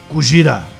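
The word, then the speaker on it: Kujira (クジラ): Mas no filme é a mistura da palavra gorira, que é gorila em japonês, com Kujira, que é baleia. – Você tá certo na pronúncia. – Gorira. É gorira, né? – É o seu japonês indiano? – Kujira. Isso. Kujira e gorira.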